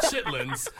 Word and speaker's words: chitterlings: For our non-African-American or black audience, Chitterlings. Pronounced chitlins.